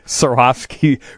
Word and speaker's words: Swarovski: For Brian, Swarovski, am I saying that right? Crystal figurines. I think we were there when someone brought one. – Yes, of those in. – Yeah, we do buy the Swarovski crystal figurines.